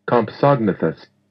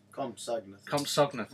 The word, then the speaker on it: Compsognathus: This is how you pronounce the one we were just about to say. – Let's just make sure it's going to work. – It should do. – Compsognathus.